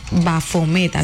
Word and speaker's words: Bafomet: De hecho, se habla de que, bueno, Bafomet, así se pronuncia aquí, es una figura simbólica que suele representarse con cabeza de cabra, cuerpo humano y elementos asociados a lo masculino y femenino.